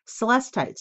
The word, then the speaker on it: Celestite: Celestite, that's how you say it.